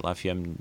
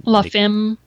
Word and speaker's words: La Femme: How do you say that? La Femme. – La Femme.